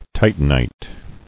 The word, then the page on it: Titanite: ti·tan·ite